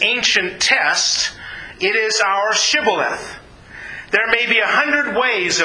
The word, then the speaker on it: Shibboleth: You see, those from Gilead pronounced the word Shibboleth. Those who were from Ephraim pronounced the word Sibboleth.